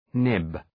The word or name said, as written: nib